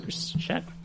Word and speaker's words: Chris Schacht: And they've announced the first ambassador is a guy called Chris Schacht, I think. S-C-H-A-C-H-T. Scott, would that be pronounced? – Yep. – Chris Schacht.